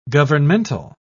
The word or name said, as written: governmental